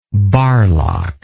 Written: Barlach

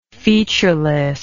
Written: featurelessness

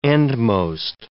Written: endmost

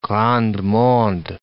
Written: grand monde

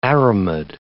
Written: aramid

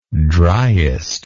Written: driest

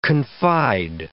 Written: confide

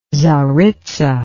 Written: tsaritza

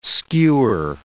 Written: skewer

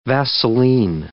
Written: Vaseline

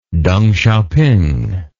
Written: Deng Xiaoping

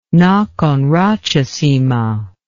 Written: Nakhon Ratchasima

